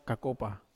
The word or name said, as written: kakopa